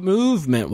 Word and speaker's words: MVMT: Trav, I want to tell you about Movement Watches. – Okay. – It's MVMT, but it's pronounced movement because it's hip and it's now and it's fashionable.